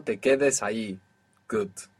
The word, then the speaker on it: ahí: The word for there is ahí. Ahí. Spelt A-H-I. And there also exists ahí spelt with double L. There's a tiny, even imperceivable difference between I, A-H-I, and I, A-L-L-I.